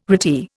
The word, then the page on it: retie: Indian